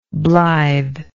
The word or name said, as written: blythe